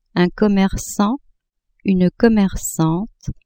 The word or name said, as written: commercant-commercante